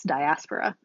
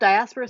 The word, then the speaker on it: diaspora: It's not diaspora, it's diaspora. – Yep. – So Early Black Diaspora Studies, a critical anthology, which is out from Palgrave Macmillan now.